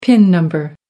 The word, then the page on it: pin number: American English